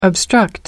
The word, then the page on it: obstruct: American English